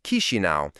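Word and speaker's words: Chișinău: Its capital, Chisinau, pronounced Chisinau, feels like a place happiness abandoned long ago.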